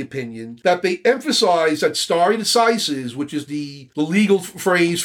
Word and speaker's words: stare decisis: It's spelled like stare, but it's pronounced stare decisis.